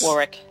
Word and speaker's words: Warwick: Warwick. For all you Americans out there, do not pronounce Warwick. the W.